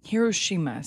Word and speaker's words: Hiroshima: Hiroshima or Hiroshima, depending on how your pronunciation goes. – Hiroshima sounds correct. – It is correct. Yeah. Yeah, that's right. That's the right answer.